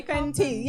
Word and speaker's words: Fenty: or Fendi. Mm. Sorry. – Hey. Fenty. I think it's Fenty. – Yeah. yeah it, is it? Yeah, no, Fenty. Yeah, I said it right.